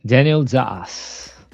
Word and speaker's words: Daniel Dass: Daniel Das.